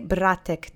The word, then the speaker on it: братек: So let's say братек together three times. Accent is on the first syllable.